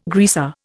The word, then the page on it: greaser: Indian